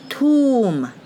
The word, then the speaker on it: tomb: 后面是 tomb，墓地、墓穴，tomb。所以，m b 它含有鼓起来的含义，但是 b 不发音，m 直接发 m。